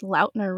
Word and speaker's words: Lautner: He said his name is pronounced Lautner recently, so oops, been doing that wrong.